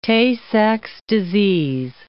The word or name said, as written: Tay-Sachs disease